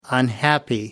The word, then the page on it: unhappy: American English